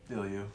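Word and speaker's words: Bilyeu: type in Impact Theory with Tom Bilo, and uh, looking up David. Go- what is it? Bill you. Bill you. Yeah, I uh, mispronounced that, but.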